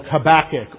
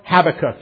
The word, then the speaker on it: Habakkuk: This morning our passage is the last three verses of the book Habakkuk, or Habakkuk. It's pronounced correctly either way.